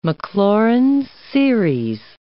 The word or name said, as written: Maclaurin series